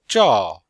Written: zhao4

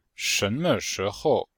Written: shen2me5shi2hou5

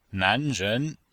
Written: nan2ren2

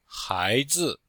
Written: hai2zi5

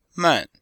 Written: man4

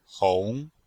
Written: hong2

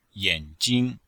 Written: yan3jing1

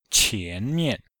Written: qian2mian5

